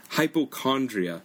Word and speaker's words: hypochondria: 그 다음에 건강염려증 hypochondria. 그래서 CH이지만 CH로 발음을 안 해요. hypochondria 이라고 안 하고 hypochondria 이라고 하시면 되고 every time he gets sick he thinks he has cancer.